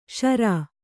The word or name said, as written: ಷರಾ